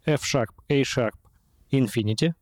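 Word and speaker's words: F♯ A♯ ∞: Альбом называется F-Sharp, A-Sharp, Infinity. Угу. Пишется гораздо легче, чем звучит. Это F, английское, решетка. – А, это решетка, точно. Да, шахп это на английском типа диез.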